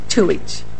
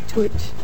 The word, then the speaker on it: Tujetsch: T-J-E-T-S-C-H? – T-U-J-E-T-S-C-H. – How did you say that again? – Tuich. – Tuich. – Tuich, correct.